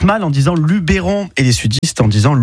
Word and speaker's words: Luberon: En provençal, en fait, le E se prononce E. Donc, si vous dites Luberon, c'est presque la bonne prononciation d'origine. Voilà. Parce que la prononciation de base en provençal, c'est Luberoun.